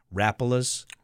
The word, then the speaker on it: Rapalas: Rapalas.